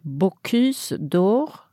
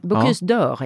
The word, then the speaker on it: Bocuse d'Or: Bocuse d'Or. Mm. Är det så man uttalar det? – Ja, det är väldigt korrekt har det faktiskt. – Var det det? Bocuse d'Or egentligen.